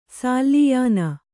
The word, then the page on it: ಸಾಲಿಯಾನ: sāliyāna